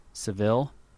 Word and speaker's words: Seville: He was the bishop of Seville in Spain. Is that how you pronounce that word in Spain? – Seville? Yeah.